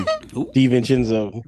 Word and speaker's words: DiVincenzo: DiVincenzo. – DiVincenzo.